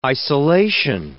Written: isolation

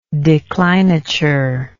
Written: declinature

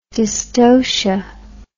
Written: dystocial

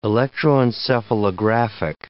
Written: electroencephalographic

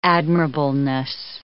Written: admirableness

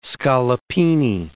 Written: scallopini

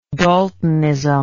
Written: daltonism

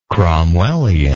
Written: cromwellian

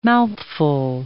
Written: mouthful

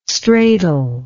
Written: stratal

